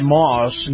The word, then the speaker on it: Maus: All righty, this portion of the program brought to you by Moss Nissan. Moss is spelled M-A-U-S, Nissan.